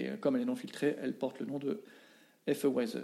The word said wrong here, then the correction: Weizen: euh, Weizen, tu le prononces mieux que moi.